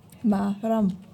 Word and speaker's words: Maram: Ma, was? Maram. Maram. Mhm. Auch wieder gerolltes R. Kommt hier in jedem dritten Wort vor.